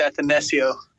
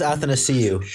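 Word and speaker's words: Athanasiou: On the Red Wings, Athanasio or whatever his name. – Andreas Athanasio. – Andreas Athanasio. – There you go. Say that Tommy's got me messed up. – Whatever, bro. Whatever. – Athanasio.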